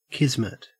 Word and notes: kismet: Australian English

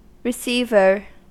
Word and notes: receiver: American English

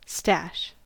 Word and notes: stash: American English

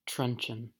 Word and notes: truncheon: American English

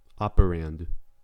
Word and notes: operand: American English

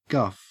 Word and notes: guff: Australian English

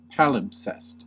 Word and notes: palimpsest: American English

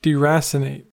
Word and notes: deracinate: American English

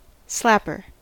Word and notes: slapper: American English

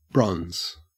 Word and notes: bronze: Australian English

